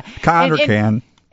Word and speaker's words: Cannes: I do say that right, Cannes, right? – Yes, yeah. Cannes.